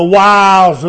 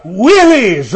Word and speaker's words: wiles: I love the story about the preacher who had a little reading problem anyhow, and he couldn't pronounce it properly. And he said, Paul said that we're to stand against the willies of the devil. Well, I'm sure the devil has some willies. But the word is wiles, deceptions, deceptive strategies.